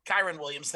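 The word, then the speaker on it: Kyren Williams: Kyron Williams, thank you.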